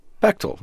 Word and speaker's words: Bechdel: It's Bechtel, rhymes with rectal.